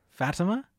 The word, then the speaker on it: Fatima: How do you say it? Help me. – Fatima? Yeah. – Okay.